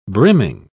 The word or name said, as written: brimming